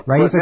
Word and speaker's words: Raisa: Kitty Dukakis versus Raisa, is that how you pronounce it? – Raisa Gorbachev.